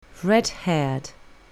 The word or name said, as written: red-haired